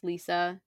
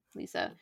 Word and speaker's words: Liza: And she is Lisa. It's spelled like Liza, but Lisa.